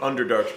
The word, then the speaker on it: Underdark: Underdark's pretty hard for you to pronounce, huh? I get it. – Super hard. It's like two syllables. – Three. Underdark.